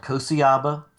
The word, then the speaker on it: Kociaba: It is called Kosiaba Fitness with a dash in between because Jaws kills the pronunciation of my name if we make it 2 words jumbled together.